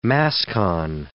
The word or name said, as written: mascon